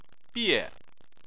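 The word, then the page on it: bie: tone 4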